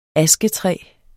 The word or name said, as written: asketræ